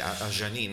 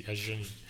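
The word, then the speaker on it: Adjani: Sì, Ajani, non si dice la D, credo. Sì, Ajani.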